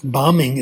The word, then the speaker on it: bombing: It turns out if there's a B at the end of a word and it comes after M, it's not pronounced. But there are exceptions, like bombing, it's not pronounced, but bombardier it is.